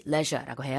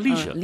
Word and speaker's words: leisure: 영국에서는 우리나라랑 똑같이 leisure라고 해요. – 네. – 근데 미국에서는 leisure? – Yeah, leisure. – leisure라고 하죠. 발음이 좀 다른데, 네.